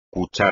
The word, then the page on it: cuchara: espagnol